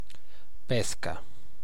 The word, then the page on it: peach: American English; [piːtʃ]; /piːt͡ʃ/